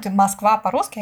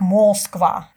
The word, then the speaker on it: Москва: А говоря о фонетике, я имею в виду отсутствие редукции у турецких студентов, то есть а, а, слово «Москва», Москва по-русски, они будут произносить как Москва.